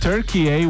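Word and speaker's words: Türkiye: I know we all say Turkey, but if you want to say it the right way, it's Turkey. – I even looked it up. I want to hear this guy. Yeah. Listen. – Turkey in Turkish. Turkey the country. Turkey. Remember, like and subscribe if you found this video useful. – I found it very interesting. – Turkey. That's right, because Carlos and I were like, is that Turkey? Because everywhere you look, it's spelled T-U-R-K-I-Y-E.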